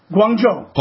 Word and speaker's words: Guangzhou: Guangzhou.